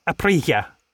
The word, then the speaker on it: Aprilia: Aprilia.